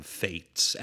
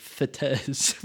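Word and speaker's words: fête: Yeah, I've always in my head been like fetes, which I know is not how it's said.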